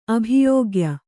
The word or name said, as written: ಅಭಿಯೋಗ್ಯ